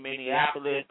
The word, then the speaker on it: Minneapolis: You know, I know my girl, Miss Queen T, is out there rocking with you in Minneapolis. Did I say that right? I always say y'all state wrong. I'm going to say Twin Cities. – Yeah, that's Minnesota, better. Twin Cities, Minnesota, Minneapolis. You said it good, though.